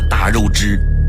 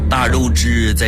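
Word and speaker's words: 大月氏: 汉武帝决定找的合作伙伴是一个叫做大肉支的国家。这个大肉支是什么样的国家呢？大是大小的大，肉是月亮的月，支是姓氏的氏。有兴趣的朋友可以查一下，这个在古代读作大肉支，是一个国家名。大肉支在匈奴的西边，后来被匈奴人是越赶越远，常年累月地进行着西游。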